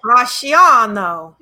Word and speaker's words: Bracciano: After deciding to hold their wedding to this idyllic perch overlooking Lake, I'm going to butcher this, Bracciano.